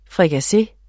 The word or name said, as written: FRIKASSE